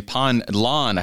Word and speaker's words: Pine Lawn: Pastor David Boisclair from Faith in Bethesda Lutheran Church in Pine Line, Missouri. Pine Lawn, I haven't said that right today.